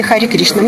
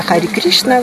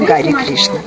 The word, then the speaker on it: Хари Кришна: Произношение Хари Кришна. Мне пришлось переучиваться на Хари Кришна вместо Гари Кришна.